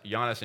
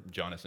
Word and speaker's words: Jannes: He says in verse 8, just as Jannes and Jambres, or Jannes and Jambres, if you want to do the hard J, opposed Moses, so also these men opposed the truth, men corrupted in mind and disqualified regarding the faith.